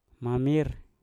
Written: mamir

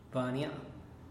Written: vania

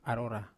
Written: arora

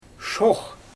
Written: ʃoox

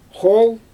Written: xol

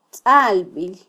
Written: tzalbil

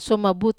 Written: suomabut

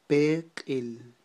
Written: peqil

